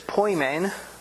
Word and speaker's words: poimen: The Greek word is poimen. Poimen, in English, that's spelled P-O-I-M-E-N.